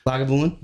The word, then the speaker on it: Lagavulin: And then I myself picked up some, how do you pronounce that, Lagavulin? – Lagavulin.